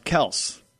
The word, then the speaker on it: Kelce: But apparently, Travis Kelce pulled a Tyrod Taylor. – What did he do? – Did he say his last name was pronounced Smith? – No, his last name is actually pronounced Kels.